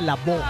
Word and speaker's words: Lavoe: Así se dice, se escribe Lavoe, pero se dice Lavoe.